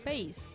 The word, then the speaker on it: Pace: ¿Cómo se pronuncia? ¿Pase? ¿Pace? ¿Pace? – Pace. – Pero eh, con, con C es Pace también.